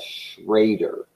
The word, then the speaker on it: Schroeder: like my wife is a, was originally a, a Schrader, but it's spelled Schroeder, and like mm.